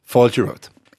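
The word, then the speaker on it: fáilte romhat: Falcha wrote. Falcha wrote.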